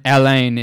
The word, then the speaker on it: Alain: Johannes is spelled J-O-H-A-N-N-E-S. – There it is. – It's very French. And then Alain is A-L-A-I-N. Alain. Alain. Alain. Alain Johans.